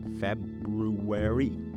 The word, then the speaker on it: February: Despite it being my birth month, it is a devilishly tricky word to pronounce, don't you think? February. Some people say February. Some people say it really quickly and just say February.